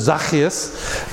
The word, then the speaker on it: Zacchaeus: There's a lot of ways. The actual way to pronounce it is Zacchaeus.